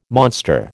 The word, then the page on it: monster: American English